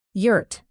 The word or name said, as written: yurt